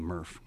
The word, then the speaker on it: MRF: We like to call them MRF. MRFs. Yeah, it rhymes with Smurf, so it makes me smile.